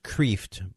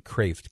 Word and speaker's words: Kreeft: I know his name looks like it would be pronounced Kreeft, but it's actually Kraft, K-R-E-E-F-T, Everything You Ever Wanted to Know About Heaven.